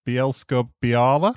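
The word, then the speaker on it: Bielsko-Biała: I'm going to go have a go at pronouncing it. Bielsko Biała. Does that sound about right? – Yeah.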